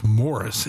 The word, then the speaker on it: Maurice: Why do I always say Maurice? His first name is Morris. It's spelled Maurice, but it's pronounced Morris.